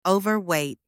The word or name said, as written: overweight